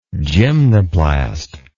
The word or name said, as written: gymnoplast